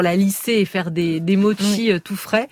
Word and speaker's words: mochi: Est-ce que je prononce bien d'ailleurs? – Oui, mochi. Bon.